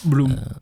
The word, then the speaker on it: Bloem: Euh, voilà, dernier album, Bloem ou Blom, je sais pas comment ça se dit. – Bloom. – Euh, Bloom, voilà. Ça doit vouloir dire fleur, je pense.